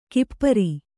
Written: ಕುಪರಿ

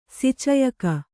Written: ಸಿಚಯಕ

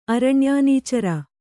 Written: ಅರಣ್ಯಾನೀಚರ

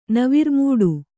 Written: ನವಿರ್ಮೂಡು

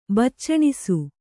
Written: ಬಾಚಣಿಸು

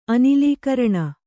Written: ಅನಿಲೀಕರಣ

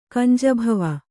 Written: ಕಂಜಭವ

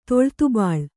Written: ತೊಳ್ತುಬಾಳ್

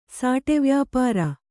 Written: ಸಾಟೆವ್ಯಾಪಾರ

